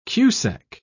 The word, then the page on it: cusec: British English